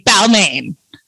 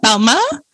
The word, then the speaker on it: Balmain: I mean, I'm like Balma. I'm like Balma. – Balma is the way to say it. If you're French, most, you know, people in France say Balma. – You don't pronounce the N, y'all.